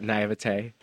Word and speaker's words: naivete: I still believe, I mean, it's my own, what is it, naivete? Is that how you say it? – Yeah, it's yeah. very good.